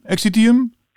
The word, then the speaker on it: Exitium: En, eh, dus, die eh, week, hoe spreek je trouwens het nieuwe album uit? Ex, ex, exitium? Exitium? Exitium, ja, Exitium. Exitium.